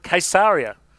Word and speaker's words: Caesarea: And our tour guide actually called it Caesarea. So there you go. That's the correct pronunciation from our Israel guide.